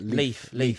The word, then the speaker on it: Leith: Leith.